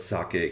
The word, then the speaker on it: sake: About years and years ago, when my mother, she used to pronounce, and I think she probably still does pronounce sake as sake, which a lot of people do. And I would correct her and I'd say, no, it's sake, because I was like an obnoxious student of Japanese back then.